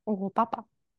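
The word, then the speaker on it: Europapa: Yeah, I think I've been pronouncing the name incorrectly for like 3 days until I actually sat down and listened to the song properly, and I was like, oh, it's oh Papa.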